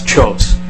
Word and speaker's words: Kjos: My name is Charles Chose. My last name is spelled K-J-O-S.